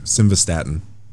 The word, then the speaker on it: simvastatin: Is it, I always say it wrong. So however I say it the first time is going to be the incorrect way. Simvastatin. – No, you're right.